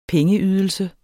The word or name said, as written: pengeydelse